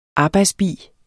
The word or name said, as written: arbejdsbi